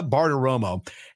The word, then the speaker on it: Bartiromo: Uh, Maria Bartimer- had, um, I cannot say her name, by the way. Maria Bartiromo, had um, James Comer and Jim Jordan on this last weekend and had some very specific questions that she wanted answered.